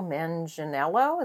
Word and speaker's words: Manganiello: Grant, Lucy Lawless, Patton Oswalt, Betty Gabriel, Joe Manganiello. Is that how you say his name? – Yep, you got it.